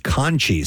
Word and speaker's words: Conche: Then you have, it's the Conchie, C-O-N-C-H-E. It's not like the conch shell. It's not spelled like that. The Conchie in Leesburg, Virginia.